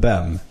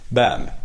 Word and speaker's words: Behm: Vi har bland annat en back i Luleå, Kalle Bem, som uttalar hans namn rätt, B-E-H-M, Bem. Bem.